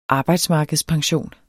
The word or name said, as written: arbejdsmarkedspension